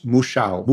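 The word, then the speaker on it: Mouchão: We're going to move to the Moncheo. Is that how you pronounce it? – No, it's Muxiao. – Muxiao. – Muxiao. – The wire is in front of the U. Muxiao, M-O-U-C-H-A. – A with the tilde.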